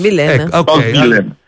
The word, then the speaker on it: Rose Villain: Come si pronuncia Rose Villain? Villain.